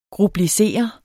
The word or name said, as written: grublisere